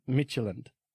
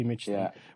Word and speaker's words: Midtjylland: Midtjylland. – Okay, FC Midtjylland, which is a massive club in Denmark, ladies and gentlemen.